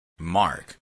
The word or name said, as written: mark